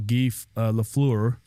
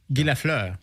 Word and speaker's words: Guy Lafleur: And he informed us just now that we lost a legendary hockey player from Montreal, Guy Lafleur. – Oh, excellent pronunciation. How is the pronunciation on that? – Guy Lafleur.